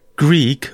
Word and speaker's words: Grug: So names like Greek, which is spelt Grug, oh.